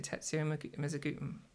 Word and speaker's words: Tetsuya Mizuguchi: Is it Tetsuya Mizuguchi? – Yes.